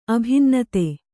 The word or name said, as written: ಅಭಿನ್ನತೆ